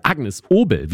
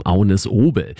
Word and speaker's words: Agnes Obel: Ich spreche von Agnes Obel oder Agnes Obel, wie man sie denn auch auf Deutsch aussprechen kann. Aber auf Dänisch sagt man zu ihrem Namen Agnes Obel.